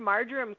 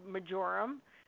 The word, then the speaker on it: marjoram: But I've heard marjoram called majorum, oregano called oregano, valor being called velour.